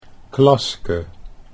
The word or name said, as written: Klaske